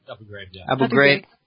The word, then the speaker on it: Abu Ghraib: When the pictures came out of Abu Ghraib, am I saying that right? Abu Ghraib? – Abu Ghraib.